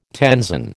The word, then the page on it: tenzon: American English